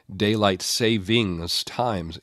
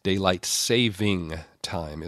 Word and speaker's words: daylight saving time: For years, I've been saying daylight savings times. And if you do that too, you like me, you need to drop that last S off of savings. Daylight saving time is the proper way to say it.